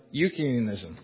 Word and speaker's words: Eutychianism: There's another, I don't even know how to pronounce it right, Eutychianism. – Eutychianism.